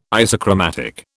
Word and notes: isochromatic: British English

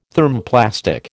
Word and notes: Thermoplastic: American English

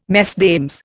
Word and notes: Mesdames: Indian

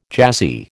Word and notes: jazzy: American English

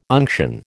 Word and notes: unction: American English

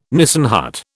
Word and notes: Nissen hut: British English

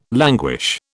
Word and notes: languish: British English

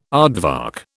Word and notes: aardvark: British English

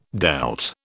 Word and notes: dealt: British English

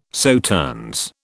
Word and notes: Sauternes: British English

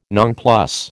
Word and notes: nonplus: American English

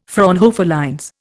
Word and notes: Fraunhofer lines: Indian

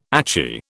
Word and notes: achy: British English

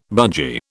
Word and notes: budgie: British English

